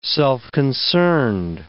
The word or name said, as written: self-concerned